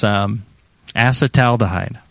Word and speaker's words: acetaldehyde: And you'd think it would be like. Acetaldehyde or something, but it's acetaldehyde. – Okay, is the one of the accepted pronunciations.